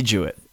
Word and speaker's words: Juett: At Andy Jewett. My last name is spelled J-U-E-T-T, at Andy Jewett.